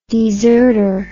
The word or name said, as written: deserter